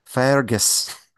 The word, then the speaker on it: Fergus: gotta say it right. Fergus.